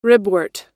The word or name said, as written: ribwort